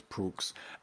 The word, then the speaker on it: Proulx: She's one of those names very hard to pronounce. I think it's Proulx.